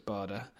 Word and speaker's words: Bader: Never sure how to pronounce it. Pretty sure it's Bada.